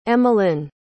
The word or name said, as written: emmalynn